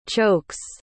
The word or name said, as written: chokes